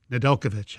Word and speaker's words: Nedeljkovic: Did I pronounce it correctly? Because I've been butchering this for the last week. – Yeah, and Nedeljkovic is good, or if you want to just say Ned.